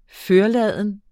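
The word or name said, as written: FØRLADENT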